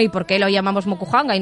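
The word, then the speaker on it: Mokuhanga (木版画): Eh, lo he dicho bien, Mokuhanga. – Mokuhanga.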